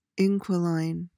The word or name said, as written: inquiline